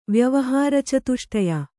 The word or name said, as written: ವ್ಯವಹಾರಚತುಷ್ಟಯ